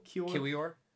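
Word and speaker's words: Kiwior: I always say this guy's name wrong. Kior. How do you say it? – Kior.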